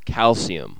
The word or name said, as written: calcium